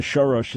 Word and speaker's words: Soros: He's Hungarian, so Soros is how they pronounce it there. So forgive my English mispronunciation.